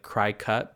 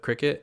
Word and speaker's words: Cricut: It's spelled C R I C U T, like cry cut, but cricket.